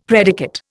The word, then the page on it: predicate: Indian